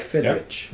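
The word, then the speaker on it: Fidrych: Fidrich, yeah.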